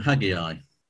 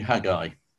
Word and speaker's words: Haggai: Now, let me apologize in advance in case I mispronounce this, because I think for the vast majority of my life, I used to call him Haggai. And only recently I've actually learned that it's a two-syllable word, and therefore it should be Haggai.